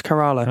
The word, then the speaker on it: Kerala: I googled how to say it, and a YouTube video told me it's Kerala.